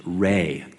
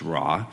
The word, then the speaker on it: Re: In ancient Egypt, the sun was worshipped as a deity named Re, commonly mispronounced as Ra. It's pronounced Re,